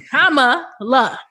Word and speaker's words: Kamala: We do know that, and Kamala, you know, Kama, that's how you pronounce her name. Mm-hmm. Kama, and then La. Kamala.